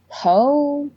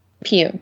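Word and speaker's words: Pugh: Florence, how do you say her last name? Is it Poe? – Pew.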